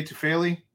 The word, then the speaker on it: Tufele: Jay Tufele.